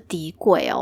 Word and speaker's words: tikoy: 永美珍他好像也有做那个底粿，就是我们所称的，就是那种甜。底粿就是红豆口味啊，过年吃的那一种，这个就可以提到说，那个菲律宾这个底粿，它的发音就是底粿哦，它就是 T I K O Y 之类的，就是念起来就是底粿。